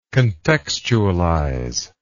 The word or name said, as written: contextualize